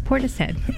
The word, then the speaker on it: Portishead: So not only do I now know he's the drummer, but I also know Portishead is pronounced Portishead.